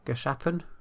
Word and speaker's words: Gachapon: Gachapon? – Gachapon.